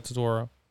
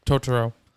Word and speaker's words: Totoro: It was right after my neighbor Totoro. I said it wrong. Totoro.